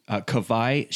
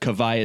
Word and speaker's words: Kawai: But Kavai is spelled K-A-W-A-I. So, of course, we're from Hawaii. – Yeah. – The Hawaiian W is pronounced like a V. It's like a German W, basically.